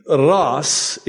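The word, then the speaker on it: Ras (رأس): In my native language of Arabic, we say Ras. In Hebrew, it's Rosh, like Jesus in Arabic is Yeshua, and in Hebrew, it's Yeshua. So they pronounce the sh, and we pronounce with the s.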